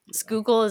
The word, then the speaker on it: Schuylkill: Schuylkill is real. That's really how you say it.